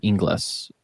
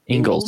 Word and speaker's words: Ingles: When I moved to Georgia, that was the first time I ever came across an Inglis. Is that how you say it? – No. – Ingles.